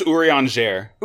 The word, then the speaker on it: Urianger: Is it pronounced Urianger? – It's pronounced Urianger.